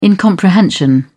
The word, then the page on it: incomprehension: British English